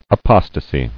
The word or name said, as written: Apostasy